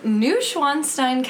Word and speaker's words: Neuschwanstein: This is Neuschwanstein Castle wine. – And you pronounce that pretty well. – Thank you. – That was really good.